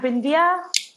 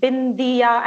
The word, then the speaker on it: Bindiya: Okay, so hi, I'm Bindiya. It's pronounced as Bindiya, and it rhymes with India, if it helps you.